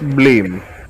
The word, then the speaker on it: Bleem: No, señores, ustedes saben, ustedes alguna llegaron a escuchar o saben más o menos qué es Blim. Se escribe B-L-E-E-M.